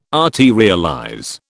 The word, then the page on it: arterialize: British English